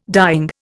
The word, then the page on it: dying: Indian